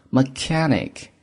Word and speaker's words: mechanic: mechanic，注意这个单词读作 mechanic，修车工或者技工的意思。